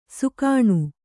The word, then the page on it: ಸಾಕಣೆ: Kannada